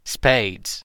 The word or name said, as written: spades